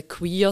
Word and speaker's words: queer: genau, also wir sprechen in Englisch aus. Äh, queer, das Gegenteil von straight.